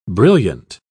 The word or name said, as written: brilliant